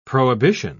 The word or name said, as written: prohibition